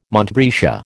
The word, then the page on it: montbretia: American English